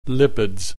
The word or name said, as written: lipids